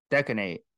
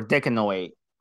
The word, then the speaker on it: decanoate: Nandrolone, Decanate, or Decanoate, depending on how you want to pronounce it.